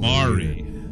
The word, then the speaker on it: Maori: the native Morai, or Maroi. I don't know how to pronounce it. – I wouldn't have a clue. – Maori, M-A-O-R-I, people who believe that the river has a direct connection to their well-being.